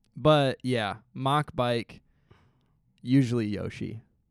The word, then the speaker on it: Mach: So that I can do the mock bike. – Okay. – Not pronounced match, by the way. I used to pronounce it that way. M-A-C-H.